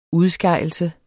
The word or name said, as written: udskejelse